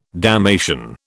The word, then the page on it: damnation: British English